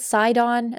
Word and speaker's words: Sidon: Is it Sidon? Is that how you say his name? – Yeah.